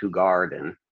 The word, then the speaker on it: Hoegaarden: Hoegaarden. Yeah. Well, technically speaking, it's Hoegaarden. You kind of accentuate the two A's at the end of it, but even I just say Hoegaarden.